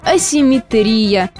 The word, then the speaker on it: асимметрия: Асимметрии или асимметрии? Кто поставил ударение на предпоследний слог, тот молодец. А теперь давайте разберемся. Ошибка ударения неизбежно появляется по аналогии. Если есть симметрия, должна быть и асимметрия. Но, как мы уже знаем, в русском языке ударение крайне подвижное и внутри одного и того же слова может перемещаться. Например, нога, но ноги, звон, но звонит. Так что будет асимметрия на греческий манер и не иначе.